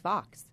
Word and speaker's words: Vaux: Your last name is spelt V-A-U-X, but it's really pronounced Vox. – Yep, it rhymes with hawks in my dialect.